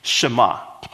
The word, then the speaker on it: Shema: So the first word is Shema.